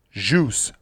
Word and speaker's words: au jus: then the proper pronunciation is a silent S. So not phonetically jus, but more like jus.